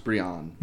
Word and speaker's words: Bryan: Oh, that's pronounced Brian. – No, it's not. Brian!